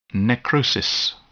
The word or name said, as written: necrosis